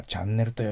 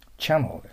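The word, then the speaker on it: channel: 障害のある人のことをね、 a disabled people, disabled person と言わずに、 a challenged, または challenged people という挑戦を受けて、それに対してね、ドンと向かっていくといういい意味に使われます。次、パッと見たらチャンネルと読みますよね。ダメよ。チャノーです。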